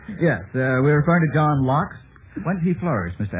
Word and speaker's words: Loch: You don't mean to tell me you're pronouncing Loch Lock? – That's on this program, we're pronouncing Loch Lock. – Where is my dead body? – If you will just stay alive for about another 12 minutes, Mr. Rother, you may do whatever you wish after this program is over. Couldn't you say, we say hastily Loch Lomond over here. How do you say it? – Loch.